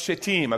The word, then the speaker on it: Shittim: It says, Early in the morning, Joshua and all the Israelites set out from, it's actually pronounced Shittim.